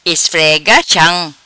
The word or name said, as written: Esfrega cham